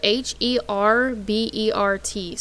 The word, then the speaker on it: Herbert: Just kind of, you know, going through it, it says that Herbert or Hebert, is it Hebert or Herbert? – This is spelled H E R B E R T. So Herbert.